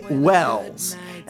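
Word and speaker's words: Welles: Okay, so today's musical guest is Jesse Wells, and it's W-E-L-L-E-S. So the reason I know it's pronounced Wells is because this album is called Wells Bells, as in AC/DC's Hell's Bells, but it's Wells Bells.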